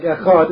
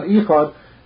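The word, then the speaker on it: echad (אחד): Also, the Rambam said you have to pronounce the ches carefully, so it should sound like echad and not ichad.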